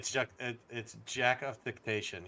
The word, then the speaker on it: jactitation: It's Jactitation.